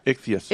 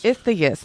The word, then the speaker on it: Ichthys: How do you pronounce that word anyways? – Ichthys. – Ichthys. That's what I was going to say, but I want to make sure that was right.